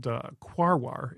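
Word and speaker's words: Quaoar: The one that we discovered then is called Quawar. It's Q-U-A-O-A-R, and nothing else that in the English language has, I think, that combination of vowels in a row. So it is a little hard for people to pronounce.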